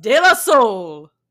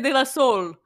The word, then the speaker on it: De La Soul: So that means that it's time to rate the humor of De La Soul. – All right. – And I had to pronounce the U there because in my mind, I always want to say De La Soul, which...